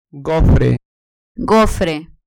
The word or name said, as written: gofre